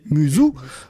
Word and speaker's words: musou: Le terme muso, prononcé Musou, musou, qui peut se traduire par sans égal.